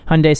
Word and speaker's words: Hyundai: Like they said that like when we first started training on Hyundai, they're like Hyundai Sunday. Say it right, because if you say it wrong on like the phone, people are gonna get pissed.